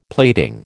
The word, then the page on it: plating: American English